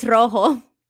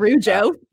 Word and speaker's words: rojo: Wait, no, it's rojo. – Rojo. – Not rujo.